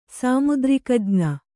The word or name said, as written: ಸಾಮುದ್ರಿಕಜ್ಞ